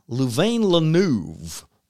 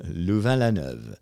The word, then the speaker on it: Louvain-la-Neuve: Can I butcher that in English? Yeah. Louvain-la-Neuve. Louvain-la-Neuve.